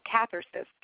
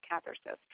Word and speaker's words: Cathercist: Um, again, if you want to check these guys out, uh, they are called Cathercist, and um, I guess I had, for whatever reason, mispronounced it earlier. – Oh, Cathercist, Cathercist. Try, sorry, trying to think about catheter.